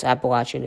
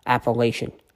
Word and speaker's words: Appalachian: Yes, Appalachian is how you say it for people who live in that area. So I've been told. Not Appalachian.